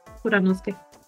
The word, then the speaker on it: Kuranosuke: Kuranosuke, Kuranosuke, bueno, Kuranosuke creo que se pronuncia. – Ajá, sí, sí, Kuranosuke.